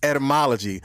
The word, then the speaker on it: etymology: I've been like, man, I keep saying etymology like there's a P in the front, but it's etymology. But the etymology of perspective is per. As you can see, the prefix is P-E-R.